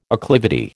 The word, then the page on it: acclivity: American English